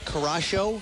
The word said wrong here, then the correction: Curaçao: It's got the little... – Curaçao.